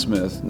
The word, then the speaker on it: Cmith: And that's C-M-I-T-H. – Yes, for sure. It's C-M-I-T-H, pronounced Smith, not C-Smith or Smith. It's just Smith.